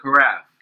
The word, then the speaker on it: carafe: The carafe. – Carafe. See, I was gonna say craft guys, but I was like, I know how to say it properly.